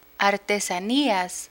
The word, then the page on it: artesanías: Spanish